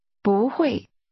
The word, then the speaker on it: 不会: 不会. 不会. – That's pronounced with first a rising tone, then a falling tone.